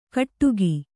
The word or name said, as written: ಕಟ್ಟುಗಿ